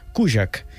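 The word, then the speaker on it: Kurzak: Atenció perquè s'escriu Kurtzak, amb dues cas i amb una zeta, però ella mateixa em va dir que es pronuncia Kujak és polonesa.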